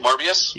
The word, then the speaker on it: Morbius: Morbius. Or did I say that right? Morbius? – Yeah.